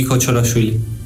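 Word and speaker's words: Kochorashvili: El último en llegar, el georgiano Giorgi Kochorafili, o como se pronuncie. – Mi nombre es Giorgi Kochorafili.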